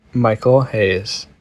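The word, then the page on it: Michael Hayes: English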